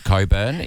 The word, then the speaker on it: Cockburn: the suburb of Coburn is pronounced Coburn. – It doesn't look like that.